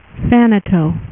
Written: thanos

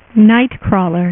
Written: nightcrawler

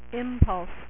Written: impulse

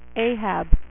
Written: Ahab

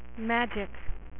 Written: Magik

